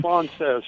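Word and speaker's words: Fraunces: Is that how you say it, Frances Tavern? – Frances, yeah, yes.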